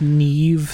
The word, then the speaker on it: Niamh: Wasn't it like Neve spelled like N I A M H? – Like Neve Campbell?